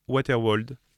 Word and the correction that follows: Wetterwald: Voilà. – Wetterwald. – Ah ouais, voilà. – C'est de l'allemand. – Je sais pas comment. – Je dirais Wetterwald.